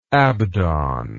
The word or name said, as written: abdon